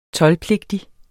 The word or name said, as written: toldpligtig